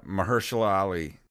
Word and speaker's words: Mahershala Ali: Mahershala Ali. – Yeah, Mahershala Ali.